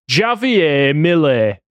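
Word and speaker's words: Javier Milei: Javier Millet. That's how you say it.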